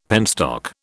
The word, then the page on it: penstock: British English